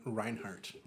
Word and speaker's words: Reinhardt: Reinhardt. – Reinhardt? – Yeah, like Overwatch.